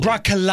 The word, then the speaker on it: broccoli: Broccoli. – I don't even use the second O when I say broccoli. It's just broccoli. – Broccoli. – But broccoli. – A two-syllable word. – Yeah. – Broccoli. – Yeah. – B-R-O-C-K-L-E-E.